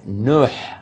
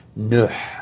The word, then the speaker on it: Nuh: And Nuh is how it's pronounced in Arabic. Nuh, with a ha sound at the end.